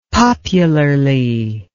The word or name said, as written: popularly